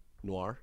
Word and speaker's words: noir: No, N-O-I. – Noir. – Noir. Off noir.